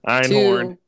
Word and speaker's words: Einhorn: Ironheart? I forget how to say it. – Einhorn. 2-1-0.